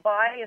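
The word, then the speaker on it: Bayh: Now Missouri, I hear that Evan Bay, is that how you say his name? – Yeah, is favored over the Rep.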